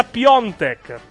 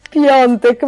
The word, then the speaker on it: Piątek: Si pronuncia Piontek. Vada. – Ah, ok, Piontek, ma non lo ricorderò mai, va bene, sì.